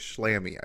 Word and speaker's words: Schlamme: His name is his name is pronounced Thomas Slammy, I believe.